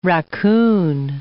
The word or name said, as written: raccoon